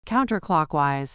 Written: counterclockwise

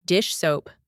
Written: dish soap

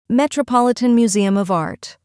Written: Metropolitan Museum of Art

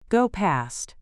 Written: go past

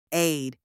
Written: aide

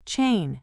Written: chain

